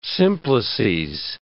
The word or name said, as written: simplices